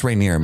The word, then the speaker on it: Rhaenyra: I'm never going to pronounce her name. – It's right. Just Rhaenyra, man. – I know, Rhaenyra.